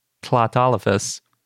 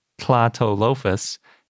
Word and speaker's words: Tlatolophus: So Tlatalophus, or Tlatolophus, if you prefer to say Parasaurolophus, is the genus name.